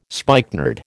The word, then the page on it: spikenard: American English